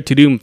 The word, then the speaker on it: Tudum: what is To Doom Festival? And I realized it's the sound. – Yeah, I didn't read it that way because it's spelled T-U-D-U-M.